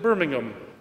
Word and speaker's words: Birmingham: After casting about for the best place to found his new community, Newman moved to the great industrial city of Birmingham, or as the British pronounce it, Birmingham, and there he founded the first oratory in England.